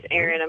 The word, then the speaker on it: Erin: My name is Erin.